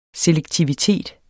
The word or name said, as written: SELEKTIVE